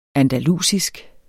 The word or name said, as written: andalusisk